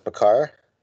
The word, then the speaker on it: Makar: So we went Sam Girard out of Colorado, who gets some pretty serious power play time with Makar. And did I say that right? – Yeah.